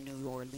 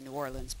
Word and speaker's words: New Orleans: And no, you're not supposed to say New Orleans. You're supposed to say New Orleans, but it rhymes with queen.